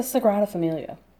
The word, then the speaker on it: Sagrada Familia: Like, was it the Sagrada Familia? Or, did I say that right? – Mm-hmm. – Or was it a certain piece in the park? Or what? – I mean, it's the Sagrada Familia.